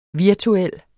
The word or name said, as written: virtuel